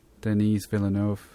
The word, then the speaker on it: Denis Villeneuve: Denis Villeneuve. Is that how you pronounce it? The guy from Arrival? – Yeah. I'm just waiting to hear one actor that's not a director that signed it is all I want to hear.